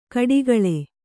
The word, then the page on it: ಕೊಟ್ಟೆಗೋಳಿ: koṭṭe gōḷi